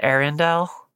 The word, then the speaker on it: Ariandel: How do you pronounce it? – I just pronounce it Arendelle. Arendelle? Very like Lord of the Rings inspired pronunciation.